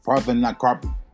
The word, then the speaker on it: Parthenocarpy: A process known as, I'm going to screw this up, Parthenocarpy. – Parthenocarpy.